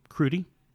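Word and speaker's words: Krudy: Blow the Man Down is directed by Danielle Crudy. Is that how you pronounce it? Crudy? – Yeah.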